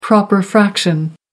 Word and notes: proper fraction: American English

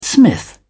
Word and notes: smith: British English

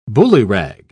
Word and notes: ballyrag: British English

